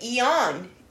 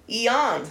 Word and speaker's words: Ian: Say, you know, if you read Spanish, you're going to pronounce it Eon. Eon.